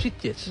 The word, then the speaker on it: Sitges: No ha dicho Sitges, ha dicho Sitges. – Lo ha dicho muy bien. – Lo ha dicho con buena pronunción catalana.